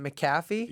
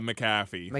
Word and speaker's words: McAfee: How do you pronounce it? Is it McAfee? – Yeah, McAfee. – McAfee.